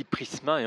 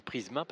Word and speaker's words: Prizma: Alors moi je dis Prisma et en fait c'est bien Prisma parce que ça s'écrit P-R-I-Z-M-A.